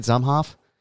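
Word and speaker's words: Zumhofe: And would Buck Zumhoffy, did I say that right, Zumhoff? – Buck Zumhoff.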